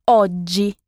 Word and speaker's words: oggi: Aujourd'hui, c'est oggi. O de G-I. Les deux G se prononcent G. Oggi.